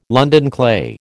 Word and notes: London clay: American English

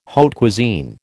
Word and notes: haute cuisine: American English

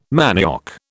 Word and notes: manioc: British English

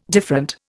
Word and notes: different: Indian

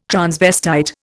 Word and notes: transvestite: Indian

